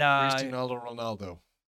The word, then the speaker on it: Cristiano Ronaldo: And Cristiano Ronaldo.